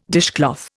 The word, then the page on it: dishcloth: Indian